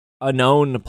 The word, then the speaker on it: Unown: When are they going to release Unown plush? I believe that's the official pronunciation.